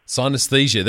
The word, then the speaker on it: synesthesia: Is mirror touch can you pronounce it? Because I can't. – Synesthesia. – There you go.